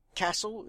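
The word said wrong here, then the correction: Cassel: is that how you say it? – Cassell. – Yes.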